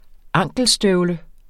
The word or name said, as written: ankelstøvle